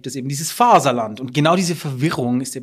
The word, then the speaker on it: Faserland: Und das ist einfach alles eigentlich nicht wahr, sondern es gibt ein Deutscher, der kann nicht aussprechen, wie man Englisch Fatherland sagen würde, sagt einfach Faserland.